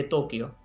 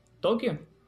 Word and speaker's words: Tokio: Como escuchó, dije Tokio, aunque en ocasiones usted oiga por ahí la pronunciación Tokio, que es impropia y debe evitarse en la lengua actual. Así que lo apropiado, el nombre adecuado de esta ciudad nipona es Tokio y no Tokio.